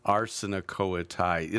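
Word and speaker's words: arsenokoitai: and that is the noun would be arsenikoetai. Arsenikoetai. – It's emphasis on the ars.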